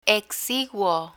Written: exiguo

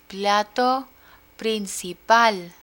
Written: plato principal

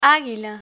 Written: aguila

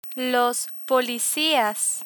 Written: policias

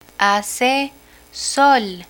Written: hace sol

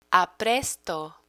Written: apresto

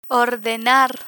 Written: ordenar

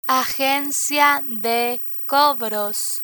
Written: agencia de cobros

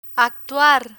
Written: actuar

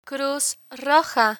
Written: cruz roja

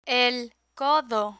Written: el codo

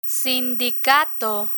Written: sindicato

